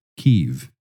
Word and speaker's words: Kyiv: Then he brought me, by divine call, to Ukraine and told me to start a church in Kyiv, pronounced Kyiv, in 1994.